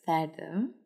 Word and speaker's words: Cerdo: It's called Gran, how you pronounce it, is Cerdo. – Oh. – Pig. But it's spelled C-E-R-D-O.